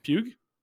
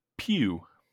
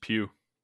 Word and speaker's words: Pugh: That's not how you say that. – How do you say it? – Pew. – No, not with a G in the end? – Yeah, just Pew.